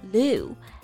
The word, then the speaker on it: lieu: Yes, lieu, L-I-E-U 这个词呢是源自于法语，原意为位置、长度的意思。In lieu of something 就是相当于 instead of，它就有替代的意思。所以 live in lieu 就可以理解为是替换的休假，也就是调休的意思嘛。